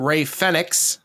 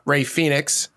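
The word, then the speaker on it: Rey Fénix: Excalibur likes to call him Ray Fenix, mm-hmm. not Ray Phoenix, because that's how I guess he pronounces. That's his Latino slang of Ray Fenix.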